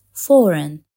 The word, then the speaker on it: foreign: فكر في الحرف B بكلمة climb أو الحرف G بكلمة foreign.